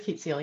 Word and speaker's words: Keet Seel: Is it Keet Seel? Is that how it's pronounced? – It is Keet Seel, yeah.